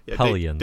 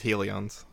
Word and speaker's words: Hellions: Hellions. – Dave has a hard time pronouncing this word. Like, exactly. From hell. from like the word hell. It's not he says helions.